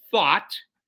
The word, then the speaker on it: Thoth: Thoth, T-H-O-T-H, I pronounce it Thought, grids to hijack the DNA from the astral fields and thwart the progression of expanding consciousness of the Earth and humanity.